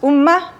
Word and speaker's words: umma: It's pronounced umma, U-M-M-A, and indeed it's not translatable, it's just a marker of direct speech.